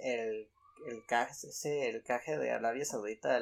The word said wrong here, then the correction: jeque: Ah, el jeque.